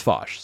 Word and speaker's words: Foch: It's spelled Foch, but it's pronounced Foch. So Nina Foch, okay.